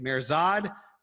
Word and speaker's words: Mihrzad: My name, AJ, M-I-H-R-Z-A-D. And if you just Google my name, AJ Mirzad, even if you spell it wrong, all the different stuff will come up.